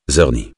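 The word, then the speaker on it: Czerny: Zerny. Ça se prononce Zerny, le C est muet.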